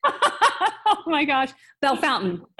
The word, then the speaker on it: Bellefontaine: So if you're pronouncing it, I don't even know how you pronounced it, but if you say that, or if you say Bellefontaine, then people will know you're not from there.